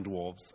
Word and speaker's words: dwarfs: I'm so sorry, because it's a lovely story, but you see, we've had the film, Snow White and the Seven Dwarfs, and then one of the dwarves was Grumpy. Wow. So it's a repetition of dwarves. – I said dwarfs. – Dwarfs. You pronounced the word correctly, but twice.